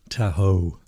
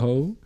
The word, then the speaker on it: Tahoe: Und zwar ich habe macOS Tahoe installiert. Habe ich es richtig ausgesprochen? – Tahoe. – Ja. Tahoe.